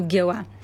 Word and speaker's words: Гела: Което също е било в миналото прилагателно, но са настъпили разни промени и сега то звучи като Гела. А в този район е характерно преместването на ударението и има и други такива местни имена, които са с ударение на последната сричка.